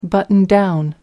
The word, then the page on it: button down: British English